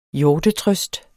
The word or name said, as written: hjortetrøst